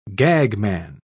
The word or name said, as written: gagman